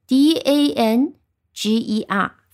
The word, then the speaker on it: danger: 拼写的话呢，我讲一下，其实也很简单，因为 danger 呢，它就是 d a n g e r，分成两部分，特别好记。因为有了极义的搭配，所以后面读音读成 j 啊。有些时候你愿意的话，也把后面这个 r 可以读的一个有个卷舌音 danger 啊，也可以。